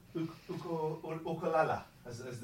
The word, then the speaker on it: ukulele: Where is my ukulele? As the correct pronunciation.